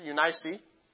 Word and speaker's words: Eunice: Eunice. Eunice is, according to the Bible pronunciation of this, Eunice.